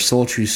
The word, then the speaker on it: Soultru: Soul True is spelled as one word, S-O-U-L-T-R-U. The song is called Neighborhood.